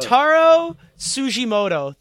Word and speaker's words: Tsujimoto: Taro Tsujimoto, with silent T.